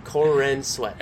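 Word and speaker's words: Corenswet: Cornsweat, is that his name? – Cornsweat. Not corn and sweat, but it's pronounced kind of like that same.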